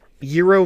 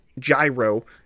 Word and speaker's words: gyro: Or I would assume it's like, first of all, the way to pronounce gyro, G-Y-R-O, is gyro making me hungry. – Yeah, right, gyro making me hungry. So first of all, if you didn't know how to pronounce it, this pun doesn't make any sense that it says gyro making me hungry.